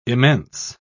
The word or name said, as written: immense